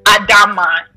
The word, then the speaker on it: Adamma: Okay, so my name online is Crystal Adama, and that's spelled A-D-A-M-M-A.